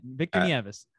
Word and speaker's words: Victor Nieves: I've got a lot of people mispronounce it like knives and Nieves, but you got it. Victor Nieves.